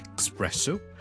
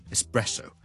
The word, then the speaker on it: espresso: Espresso 即是意式的特濃咖啡，我們這兩個字的 ES 呢，我們都不要將它讀成 X，不要 Espresso，不要 Especially， but Espresso，或者 E 都可以直接加個 S，中間不要加了個 K 音，因為 K 音呢我們 EX 我們才會有 K 音的， ES 呢我們 IS 或者 S 都可以， So Espresso Espresso，而 especially 都系冇 especially， but especially， especially。